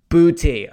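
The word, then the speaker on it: Boutte: this is a name that if I mispronounce it, it's going to sound like, frankly, like I'm saying but, and I don't want to do that. Booty.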